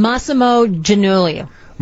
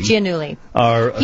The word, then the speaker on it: Giannulli: Laughlin and her husband, fashion designer, let's go to Anna DeBlantis for the correct pronunciation. – Massimo Giannulli. – Massimo Giannulli. – Giannulli.